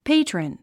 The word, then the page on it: patron: péitrən; ペェイトラン